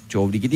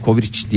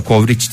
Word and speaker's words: covrigi: Covrigi değil de Kovriç diye okunuyormuş galiba. Kovriç diye okunur.